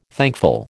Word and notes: thankful: American English

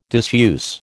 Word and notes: disuse: American English

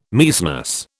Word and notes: Maecenas: British English

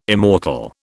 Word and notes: immortal: British English

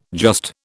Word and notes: just: British English

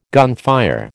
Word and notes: gunfire: American English

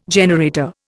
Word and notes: generator: Indian

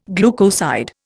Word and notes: glucoside: Indian

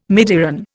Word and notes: midiron: Indian

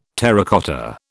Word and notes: terracotta: British English

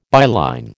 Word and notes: byline: American English

